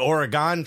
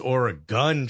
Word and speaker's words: Oregon: Said, the last syllable in Oregon is gun, not gone. You said Oregon Trail. Apparently, it's Oregon Trail. Yeah. Okay. You goober. – All right. Really got, really showed me. Oregon.